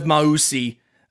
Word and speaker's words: Mausi: So like if the addition of Mausi, which people who listen to my podcast know I never get names right, so I'm glad I got that one. If Mausi, that's according to the Duke pronunciation guide on their side. So thank you, Duke.